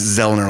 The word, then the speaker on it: Zollner: And I looked it up, and a Zollner, as I call them, of course, we pronounce it Zollner like that.